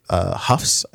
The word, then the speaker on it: Houghs: Finding the Way Home, another original program by HBO, and then Coyote Lake, and then on Hulu we have Holidays with the Huffs, I guess. How do you pronounce H-O-U-G-H-S. – Huff, yeah. – Huffs.